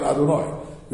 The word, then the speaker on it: Adonai: You're pronouncing it Adonai. You're saying Baruch Atah Adonai. Shema Yisrael Adonai Elohai Noron Echad. Right? You're pronouncing it as Adonai.